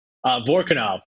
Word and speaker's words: Vorkunov: Vorkunov.